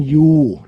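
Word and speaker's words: Ryu: เฮรันนิวส์อ๋อริวจริงๆน้องอาร์มเฮเขาชื่อริวเนี่ยมันเขาเขียนมีตัวอาร์นำหน้าแต่ว่าเกาหลีเนี่ยเขาเจ้าของชื่อเคยบอกเองนะเขาบอกว่าตัวอาร์ไม่ออกเสียงฉะนั้นเขาจะออกชื่อว่าเฮรันยูหรือไม่ก็เป็นยูเฮรัน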